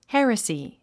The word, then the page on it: heresy: hérəsi; ヘラスィ